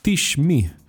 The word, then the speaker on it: Teesh: Me refiero a una aplicación que se llama Tishme tish.me, se escribe T-E-E-S-H.me.